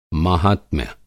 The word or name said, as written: माहात्म्य